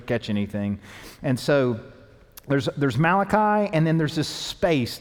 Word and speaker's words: Malachi: So there's a last book in the Old Testament, and it's called Malachi. It rhymes with sriracha, which is a sauce that you can put on food.